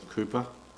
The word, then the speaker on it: Cowper: John Newton, who knew Cooper for 6 years, it's spelt Cowper, by the way, C-O-W-P-E-R, but you pronounce it Cooper.